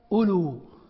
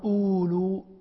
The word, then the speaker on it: أولو: قال وهي الأولى ألو، ألو هذه ألو التي بمعنى أصحاب، تقول هم ألو علم وألو فضل بمعنى أصحاب، فيزيدون الواو مع أنك في النطق لا تنطق بها ألو، ما تقول أولو ألو، فالواو زيدت فيها وهي لا تنطق، سواء في الرفع هم أولو فضل أو في النصب رأيت أولي فضل أو في الجر سلمت على أولي فضل.